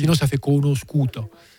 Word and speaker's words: conosciuto: C'est juste que pour prononcer conosciuto, il faut rajouter. Oui, tu supprimes le L, mais il faut mettre un I.